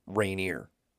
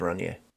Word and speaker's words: Rainier: But the chances of, you know, your local brewery making a lager that's like cleaner, crisper, and brighter than, let's say, Rainier. – Yeah. – Is. – Rainier. You're saying it wrong. It's Rainier.